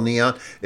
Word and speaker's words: Kneeon: And if you go searching for it on Bandcamp, it's spelled Neon. It's spelled K-N-E-E-O-N. So clever.